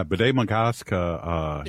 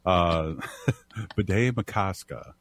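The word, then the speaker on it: Bde Maka Ska: You got me saying it wrong now. B'day Makaska. – Thank you. – Those Dakota words don't roll off the top of my tongue as they should.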